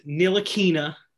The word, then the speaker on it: Ntilikina: Ntilikina.